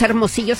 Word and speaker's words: Hermosillo: Muda le puso así a este álbum primero porque él es de Hermosillo, Sonora, y como sabemos, Hermosillo se escribe con H, nomás que la H es muda.